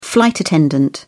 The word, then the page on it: flight attendant: British English